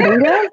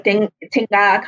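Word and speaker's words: Dingga: So Dinga is not pronounced the way that we thought it would be pronounced. – It's not Dinga? – Yeah. Like, it's not Dinga? It is like Tinga kind of thing.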